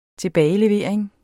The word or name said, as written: TILBAGELEVERING